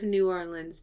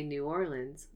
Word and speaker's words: New Orleans: Because of New Orleans' diversity, fascinating dialects and accents have evolved. There are even several pronunciations of the city's name. You can say New Orleans, like me, or New Orleans, New Orleans, or New Orleans. Often, people write the name as N-O-L-A, NOLA, which stands for New Orleans, Louisiana.